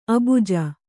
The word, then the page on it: ಅಭಿಜೆ: kannada